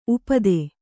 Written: ಉಪಾಧಿ

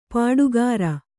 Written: ಪಾಡುಗಾರ

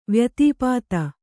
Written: ವ್ಯತಿಪಾತ

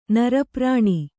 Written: ನರಪ್ರಾಣಿ

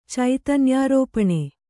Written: ಚೈತನ್ಯಾರೋಪಣೆ

